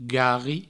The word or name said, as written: garri